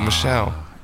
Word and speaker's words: Michel: Is it Michael or Michelle? – It's Michelle. I know it's Michelle.